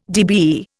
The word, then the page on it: DBE: Indian